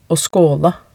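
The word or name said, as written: å skåle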